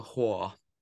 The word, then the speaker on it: Hoare: and was directed by Peter Hoare. I don't know how to pronounce that name. H-O-A-R. Hoare.